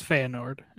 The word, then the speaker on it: Feyenoord: And then Cole Bassett heading over to Feyenoord. How do you say that? I don't even know. Feyenoord, something like that. – It's Feyenoord.